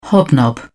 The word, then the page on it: hobnob: British English